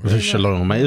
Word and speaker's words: V'Shalom: V'Shalom. It's always the V that gets me here. – I think in seminary you learned it W.